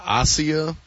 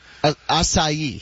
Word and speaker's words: acai: I was calling it a Asia. – Acai.